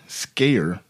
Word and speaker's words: Scaer: Robert Skaer, his last name is spelled S-C-A-E-R.